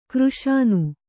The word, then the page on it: ಖುರಾಸಾನಿ: khurāsāni